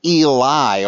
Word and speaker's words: Eli: But Eloi is Aramaic for my God, but that's not the way it sounds in Hebrew. It's Eli, or Eli, as we would pronounce it.